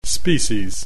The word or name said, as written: species